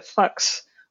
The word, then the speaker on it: Fuchs: Uh, I, I call him Fuchs, but I, have been told that I, in order to pronounce this properly, it's something closer to Fuchs which is fun to say um, for an English speaker.